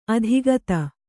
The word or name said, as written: ಅಧಿಗತ